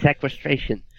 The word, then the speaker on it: sequestration: That's, how do you say it? Sequestration? – Sequestration.